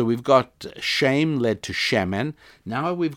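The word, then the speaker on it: Shemen (שמן): Now, if we add one letter to the end of that, the letter N for November, we get Shemen, and it's a three-letter word. Spelt S, if I were to transliterate it, I'd spell it S-H-E-M-E-N, Shemen. That means oil.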